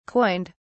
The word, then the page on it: coined: British English